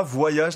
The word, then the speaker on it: voyage: On ne prononce pas voyage la même chose partout en Suisse romande? – Alors, dans certaines régions du Valais, dans certains villages du Valais, on prononce le V initial risque de sauter, donc on va parler de voyageur ou de voyage.